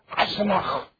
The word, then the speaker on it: Eisenach: If you're a German, you probably give it a better pronunciation, like Eisenach. That'd be the way us Germans would pronounce it.